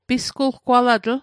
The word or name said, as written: pisgulgwalatl